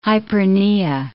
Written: hyperpnea

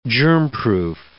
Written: germproof

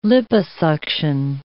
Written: liposuction

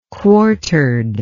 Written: quartered

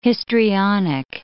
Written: histrionic

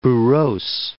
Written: Boras

